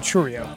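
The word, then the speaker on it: Chourio: How do you say Cheerio? – Jackson Churio. – Churio.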